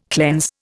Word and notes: cleanse: Indian

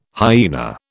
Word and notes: hyaena: British English

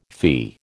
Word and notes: fee: American English

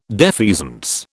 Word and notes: defeasance: British English

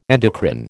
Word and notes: endocrine: American English

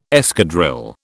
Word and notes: escadrille: British English

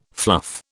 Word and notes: fluff: British English

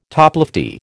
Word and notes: toplofty: American English

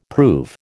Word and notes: prove: American English

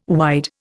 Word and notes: wide: Indian

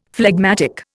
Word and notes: phlegmatic: Indian

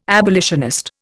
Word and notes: abolitionist: Indian

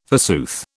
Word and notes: forsooth: British English

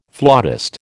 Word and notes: flautist: American English

